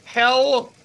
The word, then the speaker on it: Hel: This bus takes its riders to Hell, Poland. Now, that's spelled H-E-L. – Hell, Poland. – But it's pronounced hell.